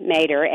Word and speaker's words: Mader: I should say, though, before the music starts, because we're coming toward the end, that Ashley Mader, M-A-D-E-R, Dr. Mader, am I pronouncing your last name right? – Yes, you are.